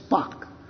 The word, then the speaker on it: Spaak: Most of you have probably never heard of a man named Paul Henri Charles Spock. No relation to Mr. Spock from Star Trek. His name is spelled a little differently. S-P-A-A-K.